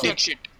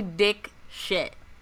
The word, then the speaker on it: Dixit: How do you say her last name? I always say Madhuri Dixit. – Dick shit. It's a terrible last name. It's actually Dick shit.